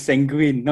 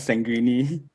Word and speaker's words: sanguine: By the way, it's sanguine, not sanguine.